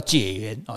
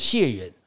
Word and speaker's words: 解元: 那乡试第一名呢叫解元啊，就是解除的解，也有人念作解元啊。